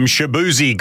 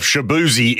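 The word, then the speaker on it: Shaboozey: If you're wondering why he calls himself Shaboozy, it is because that is essentially his surname, how it's pronounced. It's just spelled a little differently.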